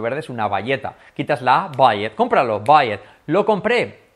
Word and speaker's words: buy it: Fijaros, curioso cómo se pronuncia cuando se pone con it, que todos los verbos van así, cómpralo. Cómpralo en presente sería buy it, como valleta.